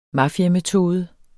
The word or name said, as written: mafiametode